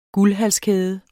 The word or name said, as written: guldhalskæde